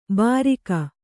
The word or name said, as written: ಭಾರಿಕ